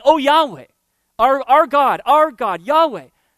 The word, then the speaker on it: Yahweh: In the original language, it's pronounced Yahweh, or some people call it Jehovah.